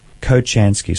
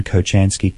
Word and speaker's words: Kochansky: Kochansky, so I'll spell that. K-O-C-H-A-N for November, S-K-I. That name again, Kochansky, K-O-C-H. A-N for November, S-K-I.